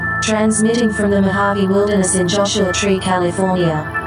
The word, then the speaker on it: Mojave: The Spanish pronunciation Mojave, in its various spellings, means beside the water.